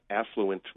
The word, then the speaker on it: affluent: But I think affluent, with the stress on the first syllable, is predominantly used.